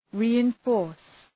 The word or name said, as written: reinforce